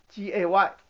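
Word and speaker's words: GAY (גיא): 好了，因为加特这个字在原文的意思就是山谷，山谷这个字在希伯来文的发音就叫该 GAY，我在黑板上写了，讲义也写了，它就叫 GAY。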